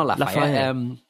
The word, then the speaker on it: Lafayette: Lafayette, yeah. You know Lafayette.